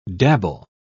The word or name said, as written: dabble